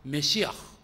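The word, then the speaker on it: メ シ ア フ (מָשִׁיחַ): ちょっとヘブライ語っぽく発音すると、メシアはメシアフ、メシアフで、ギリシャ語でキリストはクリストス、クリストス、全く発音違いますけれども、意味はいずれも同じ意味で、油注がれた者、特別の使命を帯びた者、救い主を意味しました。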